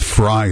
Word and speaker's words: Frey: That being said, you have people like the mayor of Minneapolis, a guy named Jason, I think it's Fry is how it's pronounced, F-R-E-Y, but I think it's Fry, who says, in essence, sorry, your thoughts and prayers, not sufficient.